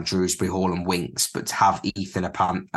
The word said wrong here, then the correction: Ampadu: I think it's Ampadu, I think is how you say his name. I can't actually say his name. It's very weird. Ampadu, that's the one.